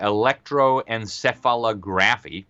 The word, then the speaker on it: electroencephalography: They use what's called EEG, electroencephalography, is apparently how you say it.